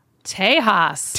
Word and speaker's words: Tejas: Now, this next album, I don't know how to pronounce this album. – Tejas. – Tejas? Is that it? – Oh, yeah. – All right. I didn't think it was Tejas, but I didn't know if it was Texas or...